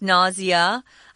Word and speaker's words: nausea: It may also cause something called nausea, or you can pronounce this nausea. I think more people say nausea. Just learn that one.